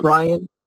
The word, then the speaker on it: Brian: A lot of times people spell my first name wrong. They spell it B-R-A-I-N, so I'm brain fried. It's my last name is spelled F-R-I-E-D, but no, it's Brian.